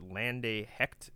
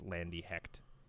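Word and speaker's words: Lande Hekt: I think you pronounce it Lande Hecht. It's like an alternative indie rock album. The album title is Going to Hell. The artist is Lande Hecht. It's L-A-N-D-E-H-E-K-T.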